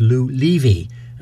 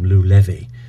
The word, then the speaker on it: Lou Levy: And I thank Norman for correcting my previously mispronunciation of the pianist's name. He's Lou Levy, and I've always called him Lou Levy.